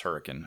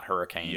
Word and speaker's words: Hurricane: But like Tokerville and Hurricane, it's pronounced Hurricane, apparently, not Hurricane.